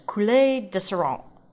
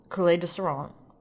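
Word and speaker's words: Coulée de Serrant: Your where is Coulée de Saran. That's spelled Coulée with the accents. Signal symbol over it, E space DE space Serrant. Now, as I said, it's a monopole, Coulée de Saran,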